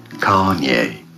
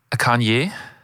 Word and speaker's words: Kanye: Kanye? Kanye. – Kanye, finde ich, sagst du super. – Ich glaube, die Betonung ist auf der ersten Silbe.